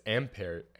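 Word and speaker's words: Ampère: Okay, it's ampere. – Well, ampere. – It's a French name. Ampere. – Ampere. Oh, that's how you say it.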